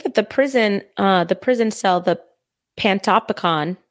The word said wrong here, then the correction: Panopticon: Did I say that right? – Uh, Panopticon. Yeah, Panopticon. Okay.